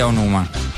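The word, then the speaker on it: Aonuma: que, nunca se pronunciar bien. Anuma o algo así. A, Onuma ¿no? – Sí, el señor Eiji Onuma.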